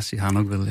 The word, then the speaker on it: Sihanoukville: Udtalte jeg byen rigtigt? – Ja, Sihanoukville, ja.